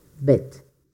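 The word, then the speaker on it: Bette: pero se pronuncia exactamente igual que en francés bestia, Beth.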